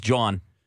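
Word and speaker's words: Jawn: Yeah, because that's how you say it in Philly. It's John.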